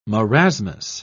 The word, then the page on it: marasmus: British English